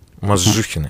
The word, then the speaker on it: Мозжухины: И у нас в гостях Александр и Мария Мазжухины.